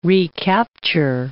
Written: recapture

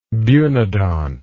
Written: bunodont

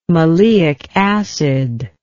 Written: maleic acid

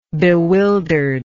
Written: bewildered

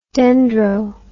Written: dendro

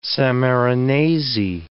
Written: Sammarinesi

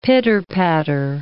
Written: pitter-patter

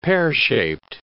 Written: pear-shaped

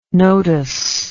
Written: Notus

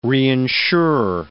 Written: reinsure

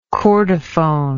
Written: chordophone

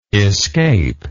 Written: escaper

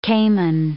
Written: caiman

